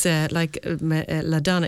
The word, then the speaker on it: LaDonna: I have to say that first because it's LaDonna, it's Madonna with an L.